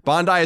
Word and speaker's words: Bondi: Bondi is pronounced Bond like James I. Bondi.